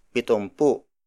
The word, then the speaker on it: pitumpu: Up next is pitumpu. Pitumpu. Pitumpu. Pitumpu is spelled as P I T U M P U.